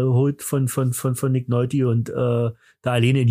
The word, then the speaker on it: Nick Nolte: Mit Nick Nolte wird ja, glaube ich, ausgesprochen. Nick Nolte, Nick Nolte, Nick Nolte.